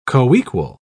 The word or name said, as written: coequal